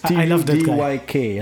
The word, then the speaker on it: Tudyk: It's a bit difficult to pronounce a surname with three consonants next to each other. – Jesus, Tudyk. – It's Tudyk, and I'm not even going to try.